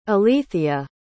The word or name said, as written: elethia